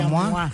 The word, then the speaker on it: Moi: Se pone, pronuncia, se escribe MOI, pero... – Se pronuncia MOA.